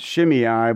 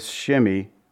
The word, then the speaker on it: Shimei: I've heard him it pronounced Shimei, but looking this up and researching it, it's Shimei, the son of Gera.